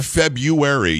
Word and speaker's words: February: But if you say February, you're forgetting the R. There is no silent R in any word in the English language.